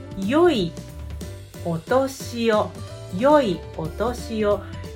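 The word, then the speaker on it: yoi (よい): It's actually a bit old-fashioned way to pronounce E. So maybe you know E. So E means good, okay? And then, but historically, we pronounce the word good, E, as yoi, okay? And then in this greeting, we use historical pronunciation, means good.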